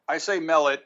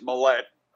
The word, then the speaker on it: Mellett: Well, I say Mellett. My older brother says Mellett. Okay. So Tom Mellett is what I usually accent the first syllable.